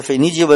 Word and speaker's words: 腓尼基: 嗯，但是特此声明一下，如果要是有学生朋友在听咱们这个节目，一定得注意，如果考试的话呢，你这个是第二声发音啊，别整错了，否则得不了分这个腓尼基文明啊，有听过的，有没听过的。